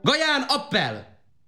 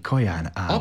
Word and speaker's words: Kaján Ábel: Gaján Appel! Gaján Appel! – Azt akarja mondani Kaján Ábel. – Appel, Appel. – Csak hogy rendkívül liftói kiejtéssel beszéli Appel a magyart.